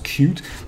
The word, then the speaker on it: Qt: and spelled large Q and a small T. It's pronounced Qt, and it's a development framework that lets you write code once and then deploy it to multiple platforms.